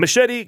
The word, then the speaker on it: machete: And Machete gives the American movie-going public another chance to mispronounce a Spanish word. – Machete. – There you go.